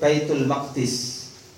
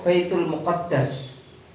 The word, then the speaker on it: Baitul Maqdis: Wuyuqalu aidan, tentang Baitul Maqdis juga bisa kita sebut Baitul Maqdis, rumah yang suci, atau Baitul Muqaddas, rumah yang disucikan.